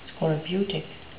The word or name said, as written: scorbutic